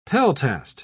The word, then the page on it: peltast: British English